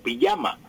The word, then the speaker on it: pijama: Pero entre nosotros siempre con Y, Eliezer, la pijama, aunque muchas veces pues escribe, usted la encuentra en libros, en periódicos, con J, pijama, pero se pronuncia sin embargo pijama, con Y.